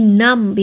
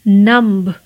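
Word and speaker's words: numb: नम्ब यानी सुन पड़ जाना सो जाना किसी चीज़ का नम्ब तो नम्ब नहीं कहना है आपको कहना है नम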